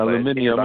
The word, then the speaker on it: aluminium: Aluminium, as the British pronounce it.